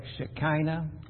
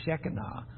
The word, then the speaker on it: Shekinah: Some people call it Shekinah. I like to say Shekinah. But however you want to pronounce it, it represents the glory of God's presence.